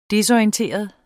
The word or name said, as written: desorienteret